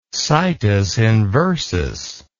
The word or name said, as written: situs inversus